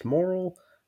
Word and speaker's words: Morrill: And the last name is Morrill. Doesn't look like it, but it's pronounced moral.